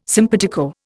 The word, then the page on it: simpatico: Indian